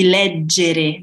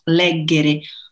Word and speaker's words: leggere: Pay attention to the double G. In that case, it's soft G. Ho scelto di leggere, not leggere. Ho scelto di leggere questo libro.